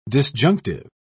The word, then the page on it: disjunctive: British English